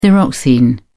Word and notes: thyroxine: British English

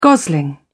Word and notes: gosling: British English

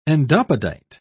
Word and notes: endopodite: British English

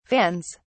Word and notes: vans: British English

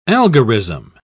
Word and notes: algorism: British English

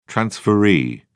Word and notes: transferee: British English

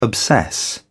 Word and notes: obsess: British English

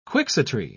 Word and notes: quixotry: British English

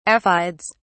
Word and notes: aphides: British English

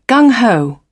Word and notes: gung ho: British English

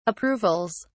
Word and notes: approvals: British English

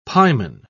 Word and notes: pieman: British English

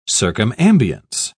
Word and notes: circumambience: British English

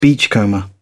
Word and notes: beachcomber: British English

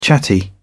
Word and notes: chatty: British English

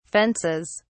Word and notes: fences: British English

